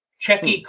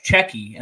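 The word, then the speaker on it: Tchéky: His name begins with a T, but I think it's pronounced Cheki, and then it's Caro is the last one.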